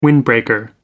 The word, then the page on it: windbreaker: American English